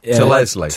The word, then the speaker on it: To Leslie: It's called Tullesley. T-O. – T-O? Name. It's not just your... pronunciation. – To Leslie. That is your pronunciation. – Have you put it into one word? To Leslie. – Yeah. – So it's to Leslie. – T-O, then the second word's Leslie.